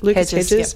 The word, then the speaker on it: Lucas Hedges: The characters are presented in a very favourable light, like Lucas Hedges' character. Lucas Hedge, is that right? Did I say his name right? Lucas Hedges. Yeah, his character's parent, well, his parents, so even though Nicole Kidman sort of oscillates between Tammy Baker at times.